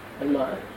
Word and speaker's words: مئة: العدد أقول العشرة المئة الألف الماء تلفظ ماء تكتب مئة أقول وجود كلها أعداد.